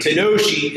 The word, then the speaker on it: tanoshi: Tanoshi. Tanoshi is the Japanese word for fun.